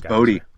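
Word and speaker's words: Bodē: Bode. I guess that's how you say it, hopefully. – I think it's Bodie. – Bodie? Okay.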